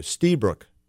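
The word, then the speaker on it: Lstiburek: Steebrook. How would you expect to spell that? Well, it isn't the way you expect unless you've seen it in writing. But I'll spell it nonetheless just for fill some time. It's L as in Larry, S as in Sam, I as in Iota, T as in Thomas. Stee, you get that right?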